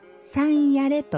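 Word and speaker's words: 幸在: 幸せいう字と存在の財と書いて三夜礼と読みますのえ。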